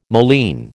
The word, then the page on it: moline: American English